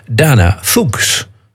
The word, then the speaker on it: Fuchs: En dan ben je heel sterk geneigd om het uit te spreken als Dana Fuchs. Fuchs. Ja, zo schreef je het. F-U-C-H-S. Maar als je dat dan opzoekt op in Wikipedia, dan staat er keurig netjes Dana Fuchs, pronounced as Fuchs. Dana Fuchs. Nou, ik hou het toch maar op Dana Fuchs.